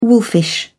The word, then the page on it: wolfish: British English